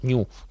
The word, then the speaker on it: GNU: Y él se inventó entonces la idea de GNU, New, que es como se pronuncia.